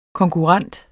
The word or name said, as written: konkurrent